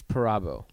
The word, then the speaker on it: Perabo: It's Perabo.